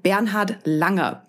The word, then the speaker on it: Bernhard Langer: With me is Bernhard Langer, or to give you the correct German pronunciation at least once, Bernhard Langer, professional golfer, golf legend, and Mercedes-Benz brand ambassador.